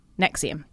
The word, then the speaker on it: NXIVM: Now it's spelt NXIVM, but it's pronounced NXIVM.